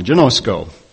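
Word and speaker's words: ginosko: It's G-I-N-O-S-K-O, and there is, the O's are pronounced long, genosko.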